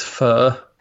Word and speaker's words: pho: I guess I've been saying it wrong for years. Pho, it's pho.